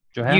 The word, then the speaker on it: Johanna: Johanna? – You did.